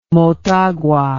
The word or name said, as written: motagua